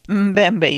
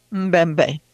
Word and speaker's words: Mbembe: Mbembe. You say the M by itself first. Mbembe. – Mbembe.